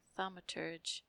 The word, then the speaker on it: thaumaturge: Thaumaturge. I was close. – Drama. – Thaumaturge. – Dramaturge. – Thaumaturge. – This is weird. – Interesting. Thaumaturge.